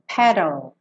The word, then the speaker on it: paddled: Aqui você tanto pode fazer o D sequinho, paddled, ou você também pode fazer o rarara, paddle, paddled, tá? E como o E não vai ser pronunciado, pensa em sair do D e já ir pro L no sol da boca. Então fica paddled, paddled, tá?